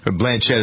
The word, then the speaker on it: Blanchett: I guess it was Blanchett is how you pronounce it, right? – Blanchett, uh-huh.